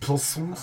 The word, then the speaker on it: Psalms: It's pronounced Psalms. – Psalm 118 is the middle chapter of the entire Bible. – That's a silent. It's Psalms.